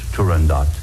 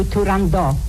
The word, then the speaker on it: Turandot: In addition to Turandot, which is pronounced the way I pronounce it, and also it was pronounced by Puccini and Toscanini, so it's really Turandot, not Turandot.